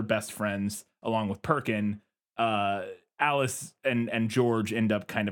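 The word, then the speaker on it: Aelis: And I wanted to know if that aspect came from the book, but then eventually it's revealed that George and Alice, and I always have to remind myself it's Alice because it's spelled in old-timey language. It's A-E-L-I-S, which I assume is where Alice comes from, probably, but it looks like A-list or something.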